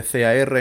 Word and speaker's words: car: Por ejemplo, la palabra coche, que se escribe CAR en inglés, en la gran mayoría de los acentos de Inglaterra se dice car. La R no se pronuncia.